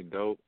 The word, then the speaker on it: Dope: Well, you can find me on everywhere under Jimmy Dope, JimmyDoughPE. And the last name is spelled that way because it's always Doe and Dope.